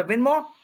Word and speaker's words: Venmo: But say that word you're saying, but spell it. – The Venmo, V-E-N-M-O.